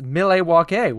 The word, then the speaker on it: Milwaukee: I said, actually, it's pronounced Milwaukee, which is Algonquin for the good land, which is from Wayne's World 2, if you've ever seen that.